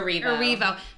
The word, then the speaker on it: Erivo: Erivo.